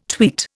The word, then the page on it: tweet: Indian